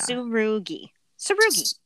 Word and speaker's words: Tsurugi: The TSU, it's Su. I think the T is silent. Yeah. – Tsurugi.